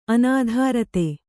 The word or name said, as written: ಅನುದ್ರುತ